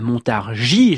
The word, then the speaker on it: Montargis: Patricia Petitbon, je le disais, née à Montargis, j'ai prononcé fautivement le S final,